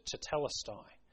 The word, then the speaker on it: tetelestai: In the Greek, that's just one word, and I'll try and pronounce it, tetelestai.